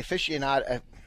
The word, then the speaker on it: aficionado: And you tell me it once, but that one, but the aficionado. – You said it right. Aficionado. I just think of fish.